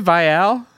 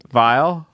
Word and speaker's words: Viall: Is that how you say it, Viall? – How do you say it? – Vile? – Vile? I say Viall.